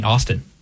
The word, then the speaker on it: Augustine: I know that he'd be fine with different pronunciations of it, because the English in older books just call him St. Austin. – Yeah. – They shorten it to Austin.